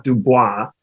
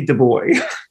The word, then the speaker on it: Dubois: which is that when Juliet first meets Francois Dubois, the joke is that she doesn't get the French pronunciation and calls him Frankie Dubois. – Right. Du Bois.